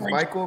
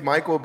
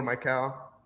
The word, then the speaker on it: Mykal: I mean, my middle name's Michael, but it's spelled M-Y-K-A-L. So you could say like Michael or Michael. So everybody just says Michael.